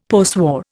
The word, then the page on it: postwar: Indian